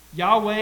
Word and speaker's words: Yahweh: Yahweh is the way it's pronounced in Hebrew. Sometimes in the Old English, we say Jehovah. Whatever it is, whenever you see L-O-R-D in all caps in your Bibles, that's the covenant-keeping God.